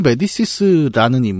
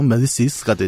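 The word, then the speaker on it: Médicis: 메디치가 이제 그 이태리식으로 읽는 거고 네. 프랑스에 넣으 메디시스가 되죠.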